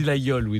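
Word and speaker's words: Laguiole: Il y a la Guiole. On dit la Guiole, d'ailleurs.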